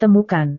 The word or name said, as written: temukan